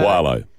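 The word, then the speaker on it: Qalo: Quayo or Qualo, how do you say Qualo.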